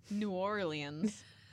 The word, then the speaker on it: New Orleans: New Orleans. Just like they say it down south. – That's how they say it in the song.